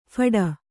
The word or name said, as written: ಫೋಡ್